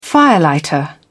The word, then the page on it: firelighter: British English